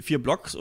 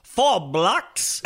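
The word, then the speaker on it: 4 Blocks: Ich habe natürlich eine Serie, von der manche nicht wissen, ob sie vier Blocks oder Four Blocks ausgesprochen wird. Ich glaube, die richtige äh, Aussprache ist Four Blocks.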